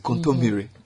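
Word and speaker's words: kontomire: I like that you pronounce it, contomire.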